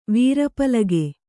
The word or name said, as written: ವೀರಪಲಗೆ